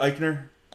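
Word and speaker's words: Aichner: Barzell and Eichner, is it Eichner? – Yeah.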